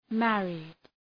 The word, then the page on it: married: ‘mærıd